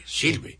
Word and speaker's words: Silves: Silves, tal como se pronuncia, con V, Silves.